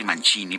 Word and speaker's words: Mancini: Estamos, estamos celebrando a Henry Mancini, Henry Mancini, perdón, quien eh, él utilizaba esa pronunciación, la italiana.